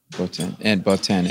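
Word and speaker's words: Botín: And you take us to the Sobino de Bobin. I'm probably mispronouncing it. – Botin. – And it's the... – Or Botin. – Botin. – Botin would be French. Botin.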